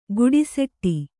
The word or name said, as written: ಗುಡುಸೆಟ್ಟಿ